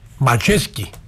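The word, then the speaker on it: Majecki: Ο Πολωνός, ο τερματοφύλακας, είναι καθόλου έτοιμος και θέλω το επίθετο του, πώς προφέρεται, είναι Μαγιέτσκι. – Μαζέσκι.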